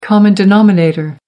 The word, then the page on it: common denominator: American English